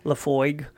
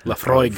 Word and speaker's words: Laphroaig: Oh, so much the the tart, the tart and the lime and the slight smoke from the uh, what is it, Laphroaig. Laphroaig. Laphroaig. I can't. You gotta say it like a like a like a Scottish person. I can't roll my R's and I'm.